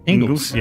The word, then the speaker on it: Ingles: Ingles.